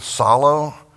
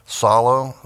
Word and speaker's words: psallo: That word sing is the Greek word solo. We might spell that P-S-A-L-L-O or L-L-O-W, solo, the P is silent.